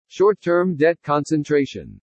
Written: SHORT-TERM DEBT CONCENTRATION

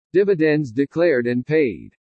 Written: DIVIDENDS DECLARED AND PAID

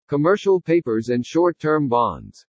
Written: COMMERCIAL PAPERS AND SHORT-TERM BONDS

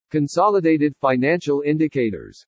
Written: CONSOLIDATED FINANCIAL INDICATORS

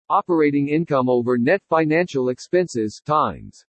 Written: OPERATING INCOME OVER NET FINANCIAL EXPENSES TIMES

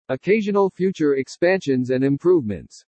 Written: OCCASIONAL FUTURE EXPANSIONS AND IMPROVEMENTS

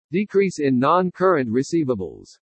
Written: DECREASE IN NON-CURRENT RECEIVABLES

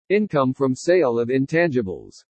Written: INCOME FROM SALE OF INTANGIBLES

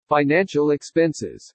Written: FINANCIAL EXPENSES

